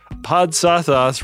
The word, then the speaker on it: Podsothoth: You can search for London Lovecraft Festival online to find tickets for that, or look for Podsothoth : a Lovecraft book club in your podcatcher of choice. And I'm told that Podsothoth rhymes with Rod's Dropcloth.